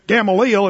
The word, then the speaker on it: Gamaliel: Gamaliel, if you want to be really savvy in your Greek pronunciation. Or you can say Gamaliel, if you're from Oklahoma.